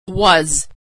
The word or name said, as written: was